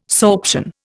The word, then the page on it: sorption: Indian